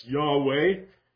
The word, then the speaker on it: Yahweh: And that is pronounced by us, Yahweh.